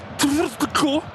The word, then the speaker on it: Tvrtko: Est-ce que c'est Stefan Trutko, TVRTKO, le premier.